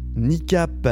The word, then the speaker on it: Kneecap: Il est le coup de cœur de la semaine, qui, cette semaine, ne pouvait être autre que le nouvel album de NICAP. Alors, ça s'écrit K-N-E-E-C-A-P.